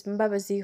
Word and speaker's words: Mbabazi: My name is Mbabazi Humura Ruth.